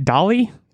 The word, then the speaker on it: DALL-E: Is it DALL-E? Is it like WALL-E? – I think so, yeah.